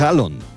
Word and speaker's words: Haaland: a decir el nombre real de Erling Haaland, que es Halon. La doble A en los idiomas escandinavos se pronuncia como una O.